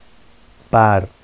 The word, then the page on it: bar: Vafsi